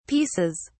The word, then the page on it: pieces: British English